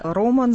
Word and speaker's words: рома: Ну, цыгане или роман за Европе, а у нас в России чаще говорят рома, поскольку это на цыганском языке правильное ударение.